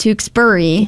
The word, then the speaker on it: Tewksbury: Like I remember even doing like traffic sometimes, like, for a while, I said Tewksbury. – Yep. – And someone's like, it's Tewksbury.